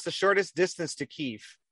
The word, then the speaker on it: Kyiv: It's not Kiev, like that's everybody how, else that, does it? – That's how the Russians pronounce it.